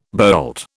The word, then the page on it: boult: British English